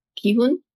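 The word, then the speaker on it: Gi-hun: And then there's one more with, how do you say his name? – Ki-hun.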